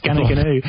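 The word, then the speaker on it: Goonoo Goonoo: And it's actually spelled Gunu Gunu. – No, I don't. – It needed a Y, but it's pronounced Gunnagunu.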